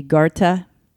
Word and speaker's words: Goethe: And that was this, I don't know how to say his name, G-O-E-T-H-E, Goethe.